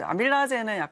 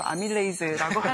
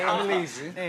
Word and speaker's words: 아밀레이즈: 이제 아밀라제는 약간 좀 구식 발음이고 아밀레이즈라고 하는데. – 아밀레이즈.